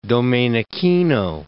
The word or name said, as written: Domenichino